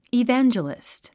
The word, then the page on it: evangelist: ivǽndʒəlist; イヴェンジャリスト